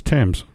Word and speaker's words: Thames: It's not Thames, it's Thames. – Well, if you took the TH and made it a J.